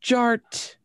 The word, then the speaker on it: DJ Art: And my name is Jared, aka DJ Art, with two T's for a double dose of that tink tink. The D is silent, so it's just Jart.